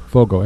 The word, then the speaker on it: Foggo: So this Dusty, is it Fogo? – Fogo. F-O-G-G-O. – Okay.